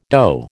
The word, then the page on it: dough: American English